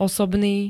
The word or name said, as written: osobný